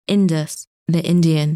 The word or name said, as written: Indus